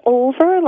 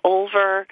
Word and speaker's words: Olver: It's Olver, like I don't even know, Olver. – Olver. – Olver, yeah, right. – O-L-V-E-R. – Right. It's Oliver without the I. – Yeah, O-L-V-E-R.